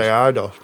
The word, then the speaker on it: Eidos: How would you pronounce it? – I would say Eidos.